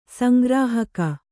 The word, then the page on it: ಸಂಗ್ರಾಹಕ: sangrāhaka